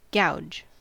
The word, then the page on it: gouge: American English